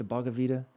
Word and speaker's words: Bhagavad Gita: And I know that he's going to say that that's like in the, is it the Bhagavad Gita?